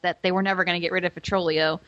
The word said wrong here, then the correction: Vitolio: I think it's Vitolio. – Vitolio. You're probably right. I was putting an extra R in there.